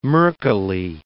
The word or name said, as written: murkily